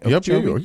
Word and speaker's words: Okeechobee: Am I saying that right? – Okeechobee? Yep, yeah. You're nailing it.